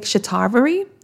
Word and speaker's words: Shatavari: So I take Shatavari. Let me say it. Did I say that right? Because, you know, I see stuff. Yep, I did. Shatavari, I take Boswellia.